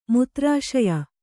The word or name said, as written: ಮುತ್ರಾಶಯ